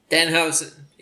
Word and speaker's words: Danhausen: Brian Shoemaker says Danhausen. Did I pronounce that right? – Danhausen, yes.